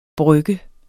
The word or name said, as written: brygger